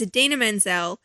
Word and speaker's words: Idina Menzel: And it has, for anybody who is not kind of up there with the Broadway world, it has Idina Menzel, mm-hmm.